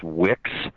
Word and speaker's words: WICS: I wrote this essay called Worst Case Scenario Syndrome, which is pronounced WICS.